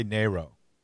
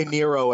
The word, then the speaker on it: Nero: How do you pronounce his name? I say Nero. – We say Nero, I think. Nero. The way I say it.